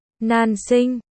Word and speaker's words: Nan Sinh: Nan Sinh, Nam Hạnh, phát âm là Nan Sinh, còn khó là Nan, Nan, may mắn là Sinh, Sinh.